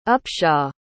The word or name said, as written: upshaw